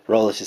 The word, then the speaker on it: Rowlett: Rowlett is spelled Rowlett.